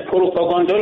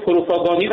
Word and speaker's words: پروپاگاندا: حالا آقای رئیسی که خب پروپاگاندا رو پروپاگانی قبلا گفته بود، لوکوموتیو لوکوموتیر گفته بود که مورد تمسخر واقع شد که ایشون اونقدر با تیر تفنگ سر و کار داره.